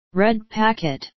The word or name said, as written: red packet